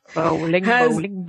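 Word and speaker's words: Bolling: I hope I said that right. Bowling? I can't get names. – Bowling. – Bowling. Yes.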